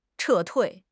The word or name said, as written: 撤退